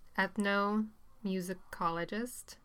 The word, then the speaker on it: ethnomusicologist: So you are a, I'm probably going to say this wrong, ethnomusicologist.